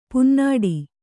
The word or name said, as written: ಪೊನ್ನುಡೆ